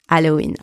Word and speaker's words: Halloween: And by the way, we do not pronounce the H in Halloween. I know that you pronounce it in English, but in French, because we don't pronounce the H, it is silent.